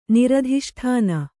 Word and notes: ನಿರಧಿಷ್ಠಾನ: Kannada

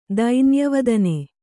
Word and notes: ದೈನ್ಯವದನ: dainya vadana

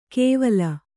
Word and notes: ಕೆವಲ: kevala

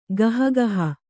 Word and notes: ಗಹಗಹ: gaha gaha

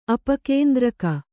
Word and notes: ಅಪಕೇಂದ್ರಕ: apakendraka

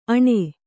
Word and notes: ಆಣೆ: Kannada

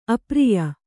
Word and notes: ಅಪ್ರಿಯ: apriya

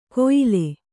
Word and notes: ಕೊಯಿಲೆ: koyile

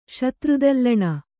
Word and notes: ಶತ್ರುದಲ್ಲಣ: kannada

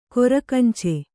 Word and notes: ಕೊರಕಂಚೆ: korakance

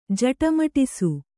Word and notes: ಜಟಮಟಿಸು: jaṭamaṭisu

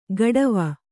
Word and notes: ಗಡವ: gaḍava

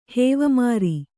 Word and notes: ಹೇವಮಾರಿ: hēvamāri

